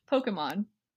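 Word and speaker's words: Pokémon: Pokemon. I don't accentuate that middle syllable at all. – No, but that vowel is much more of the eh than the e. – Yeah, for me it's an eh.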